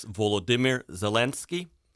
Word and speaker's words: Volodymyr Zelensky: Well, first, I would like to say that it's Volodymyr Zelensky, just like the capital of Ukraine is Kyiv, and I use Ukrainian pronunciations for all names. I do not use Russian pronunciations when I talk about events in Ukraine.